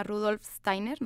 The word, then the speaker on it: Rudolf Steiner: Rudolf Steiner, no sé si lo pronuncie bien.